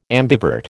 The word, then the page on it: ambivert: American English